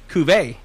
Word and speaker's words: cuVer: But I will make sure I pronounce this correct, cuVer.